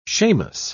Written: shamus

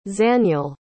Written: Zaniel

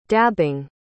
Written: dabbing

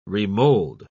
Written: remold

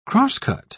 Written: crosscut

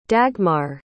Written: dagmar

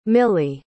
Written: milli

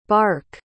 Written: barke

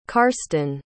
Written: karsten

